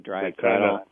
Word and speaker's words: dryad saddle: Is it pronounced a dyad saddle? – And the dryad saddle, Yeah, they kind of have an odor almost like watermelon rind.